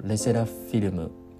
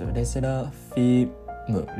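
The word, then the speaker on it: LE SSERAFIM: でかつあのこれ k p o p ファンの方チェックしたかわからないですけどレセラフィルムこれ発音合ってますレセラフィムレセラフィルムじゃないね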